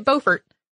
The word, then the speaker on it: Beaufort: B-E-A-U-F-O-R-T, probably you say it Beaufort.